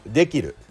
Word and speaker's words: できる: 発音しますできるでにアクセントが来て切るが下がるんでねキーとるのところが下がるんだ